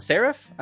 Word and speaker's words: Seraph: The Seraph? Am I saying that right? – You are saying that correct.